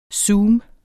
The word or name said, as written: zoom